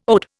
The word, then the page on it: ode: Indian